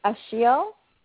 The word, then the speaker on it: Ashil: My name is Ashiel, A-S-H-I-L. – A-S-H-E-I-L, Ashiel? – No, I-L, yes. – Okay, am I pronouncing it right? It's Ashiel? – Perfect.